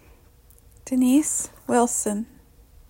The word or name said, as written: Wilson Denise